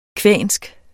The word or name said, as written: kvensk